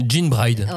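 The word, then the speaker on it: Gene Bride: C'est pas les gènes, c'est Jean. – Moi, je pense que c'est Jean. Mais euh, Jean Bride. – comme un jean.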